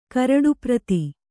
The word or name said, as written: ಕರಡುಪ್ರತಿ